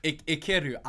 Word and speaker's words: Ikiru: I-K-U... You just darn it. Hold on. Ikiru. I-K-I-R-U.